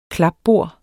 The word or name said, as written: klapbord